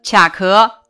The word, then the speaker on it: 卡壳: 本单元出现的多音字如下：卡组词卡壳、关卡；卡组词卡片、卡车。